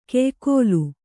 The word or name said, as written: ಕೆಯ್ಕೋಲು